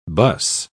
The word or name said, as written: buss